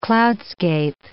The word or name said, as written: cloudscape